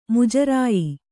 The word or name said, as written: ಮುಜರಾಯಿ